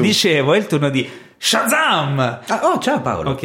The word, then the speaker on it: Shazam!: Questa settimana è il turno di Shazam!